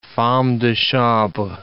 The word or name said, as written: femme de chambre